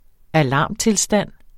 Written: alarmtilstand

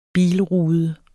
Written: bilrude

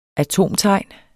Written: atomtegn

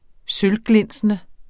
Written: sølvglinsende

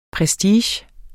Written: prestige

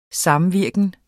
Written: samvirken

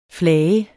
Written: flage

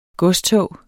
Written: godstog

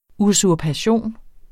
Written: USURPATION

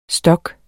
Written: stok

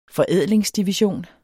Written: forædlingsdivision

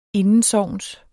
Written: indensogns

